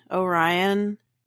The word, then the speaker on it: Orion: My name is Haley Orion, like the constellation Orion.